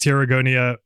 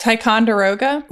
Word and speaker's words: Ticonderoga: Tarragonia." How do you say that? – Ticonderoga.